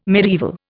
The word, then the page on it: mediaeval: Indian